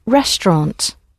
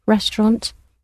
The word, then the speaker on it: レストラン: The word for restaurant in Japanese has actually been taken directly from the French, so it sounds much closer to French than the English pronunciation of it. Restaurant.